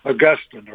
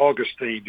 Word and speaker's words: Augustine: Many of your listeners will recognize the name Augustine or Augustine, depending on whether you're from the South Augustine, or the more formal pronunciation of Augustine.